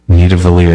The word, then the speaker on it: Nidavellir: Back at it for another week, and we are going to be reviewing a very dwarfy game today, and that is Nidavellir. – Ooh, say that again. – Nidavellir, am I saying that right? – Yes, you caught on.